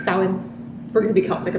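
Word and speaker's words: Samhain: I know it's called Salin. – You did say it properly earlier today. And I did. Yeah, because I know what it is. And it's spelled like Sam Hain.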